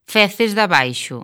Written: Feces de Abaixo

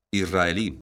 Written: israelí

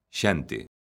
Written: xente

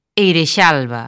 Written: Eirexalba

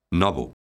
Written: novo

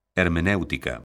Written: hermenéutica

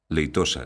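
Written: leitoso

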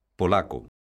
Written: polaco

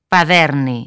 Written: Paderne

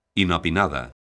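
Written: inopinado

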